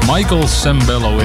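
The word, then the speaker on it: Michael Sembello: De opvolger van Flashdance komt uit dezelfde film, Michael Sambello. Als ik dat goed uitgesproken heb. Laat nog eens zien, toe Sion. Michael Sambello inderdaad.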